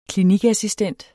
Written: Klinikassistent

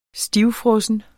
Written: stivfrossen

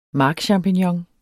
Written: markchampignon

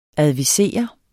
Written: advisere

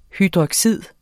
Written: hydroxyd

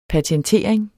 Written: patentering

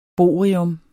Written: bohrium

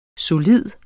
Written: solid

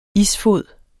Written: isfod

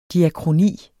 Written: diakroni

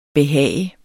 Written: behag

